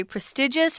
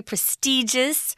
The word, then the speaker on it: prestigious: Now, despite its prestige, and we're talking about the school, if you're talking about a school that is very prestigious or very prestigious, it's pronounced both ways, just means it's highly respected.